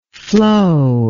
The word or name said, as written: flowability